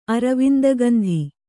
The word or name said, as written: ಅರವಿಂದಗಂಧಿ